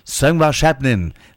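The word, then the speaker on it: Söngvakeppnin: And that song came second in the Icelandic national final, Söngvashapnin. That's how it's pronounced, I learned, and from 2000, in fact, 23 years ago.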